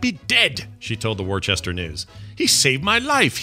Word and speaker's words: Worcester: Worcester. It's a common thing, but it's you basically pronounce the first five letters as worse, and then the last four letters as stir.